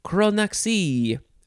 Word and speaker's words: chronaxie: And then our last word is chronaxie. Chronaxie? Chronaxie? You could spell it C-H-R-O-N-A-X-I-E. I'll say chronaxie.